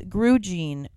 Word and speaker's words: Grugene: It's Grugene. Rhymes with blue jean.